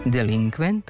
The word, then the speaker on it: delinkvent: Delinkvent, kontingent i korespondent imaju kratko uzlazni akcenat na pretposlednjem slogu, jer prema pravilima naše akcentuacije poslednji slog ne može biti akcentovan.